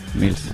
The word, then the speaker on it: Mills: Yo decía Miles con toda la intención de pronunciarlo bien. – Mills.